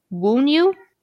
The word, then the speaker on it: Wunyu: Alrighty then, and now we go on to Rune of the Day, and it's ironically, this one, it's the symbol kind of looks more like a P, but it actually corresponds to the letter W, like the W sound. – Oh, okay. – And so it's pronounced Wunyu.